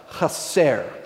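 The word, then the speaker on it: chaser (חסר): The Hebrew word here underneath want is a word that's pronounced chaser, chaser.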